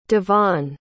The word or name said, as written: devonn